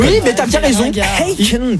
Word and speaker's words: Akon: Oui, il faut le prononcer aussi à la française, je veux dire. Oui, vit dans un pays de droit et de valeur. Et Akon que ça se prononce, et c'est ça, Akon.